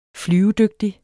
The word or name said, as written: flyvedygtig